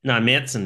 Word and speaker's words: Märzen: Yeah, a Mazen, Märzen is how you pronounce the style.